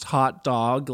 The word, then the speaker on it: Oat Dog: It is pronounced, so I guess you could say it's stylized as Oat Dog, pronounced Hot Dog, like the trash that we truly are.